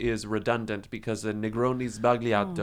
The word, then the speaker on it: sbagliato: Sbagliato, you know? – Negroni sbagliato. – Sbagliato. See, it's not Sbagliato as they said. – No, there's no it. – G. It's you don't sbagliato. Pronounce the G.